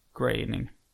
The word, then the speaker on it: Groening: Så då läste jag att det ska uttalas som färgen grå. Groening. – Groening menar du väl? – Nej. – Alltså grå. – Nej, det ska på grey på engelska.